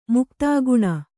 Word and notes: ಮುಕ್ತಾಗುಣ: Kannada

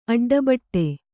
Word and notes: ಅಡ್ಡಬೊಟ್ಟು: aḍḍaboṭṭu

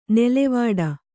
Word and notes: ನೆಲೆವೀಡು: nelevīḍu

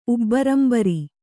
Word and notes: ಉಬ್ಬರಂಬರಿ: Kannada

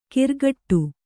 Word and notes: ಕಿರ್ಗಟ್ಟು: kirgaṭṭu

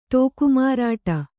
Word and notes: ಠೋಕಮಾರಾಟ: ṭhōka mārāṭa

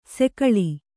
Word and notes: ಸಿಗ್ಗಾಳಿ: siggāḷi

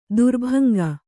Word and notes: ತ್ರಿಭಂಗಿ: tri bhaŋgi

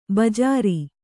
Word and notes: ಬಜ್ಜರ: kannada

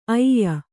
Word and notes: ಐಯ: aiya

